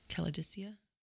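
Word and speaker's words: Kalidasa: This has got both the big expansions, so the Blue Sun and the Caladesia expansion. – Yeah, of course. Did I say that right? Caladesia? – Yeah.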